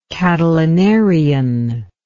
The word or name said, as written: Catilinarian